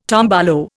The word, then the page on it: tombolo: Indian